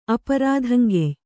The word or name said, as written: ಅಪರಾಧಂಗೆಯ್